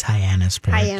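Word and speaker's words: Hyannis Port: There's all the ways to mispronounce that. – I know. Just say it for me. – I believe it's Hyannisport.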